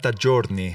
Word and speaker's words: giorni: How do you say days? – 40 giorni. – Giorni. Yeah, so like... – Like a journal.